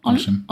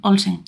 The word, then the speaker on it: Olsen: Olsen. Olsen.